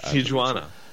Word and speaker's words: Tijuana: I pronounce the J in Tijuana, by the way. I just want you to know that. – Tijuana.